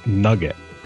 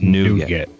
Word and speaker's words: nougat: When I was also one of those, the first time I heard it, I was like, "You mean nugget?" – No, like, no, it's nougat. Nougat. – I was like, "You're just pronouncing it funny." No, it's actually nougat.